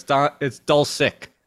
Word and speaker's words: Dulcich: Dulcich. It's a sick. Dulcich. – I don't like it. It's kind of almost like Doncic. It's Doncic, but with a sick. So it's Dulcich.